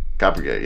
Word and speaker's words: Kurapika: Kurapika. I'm going to butcher it. – Kurapika. – Kurapika, yeah. I can't say it very well. So Kurapika.